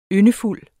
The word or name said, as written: yndefuld